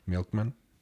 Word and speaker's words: MLKMN: Y mm, no sé cómo se pronuncia esta madre, es MLKMN, Milky Men. Milky Milkman. Milkman. Milkman.